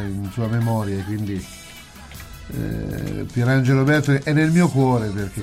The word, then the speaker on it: Bertoli: Perché tu hai scelto questo pezzo di Pierangelo Bertoli o Bertoli, non mi ricordo, la pronuncia c'è l'accento spostato. – Bertoli.